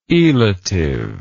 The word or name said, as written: elative